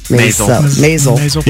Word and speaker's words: Maisel: Maisel. Yeah. Rhymes with nasal.